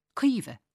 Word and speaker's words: Caoimhe: Which brings me to the thorny matter of how to pronounce any name with the letters A-O-I in it. Aoife, for example. Saoirse. Caoimhe. Naoise. The A-O-I sound in Irish is pronounced like a double E in English, and people have got used to that.